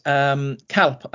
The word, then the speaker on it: Calpe: There are two ways to pronounce it, depending on whether you're using the Alicante language or Valencian language, Valenciano maybe. They call it Calpe without the E, and in Castellano would be Calpe.